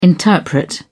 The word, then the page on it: interpret: British English